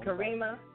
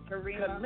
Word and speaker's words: Karima: I get to you know, chop it up with an artist like the D-Fly and now Kamira. I know I said it wrong. – Karima. – But, you know, Karima. – Kalima. – Mm-hmm. – All right. – Karima. K-A-R. Karima. – Karima, like Kareem. Okay, like Karima. Okay.